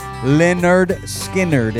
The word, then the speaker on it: Lynyrd Skynyrd: And this one's titled, pronounced Lynyrd Skynyrd, and they put it in phonetics.